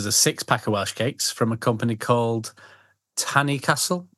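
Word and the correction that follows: Tan-y-Castell: Am I saying that right? – Do you mean Tannycastell? – Tannycastell.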